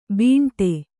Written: ಬೀಂಟೆ

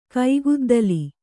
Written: ಕೈಗುದ್ದಲಿ

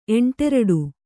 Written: ಎಂಟೆರಡು

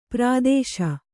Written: ಪ್ರಾದೇಶ

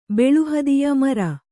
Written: ಬೆಳುಹದಿಯ ಮರ

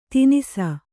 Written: ತಿನಿಸ

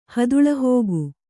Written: ಹದುಳಹೋಗು

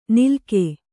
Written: ನಿಲ್ಕೆ

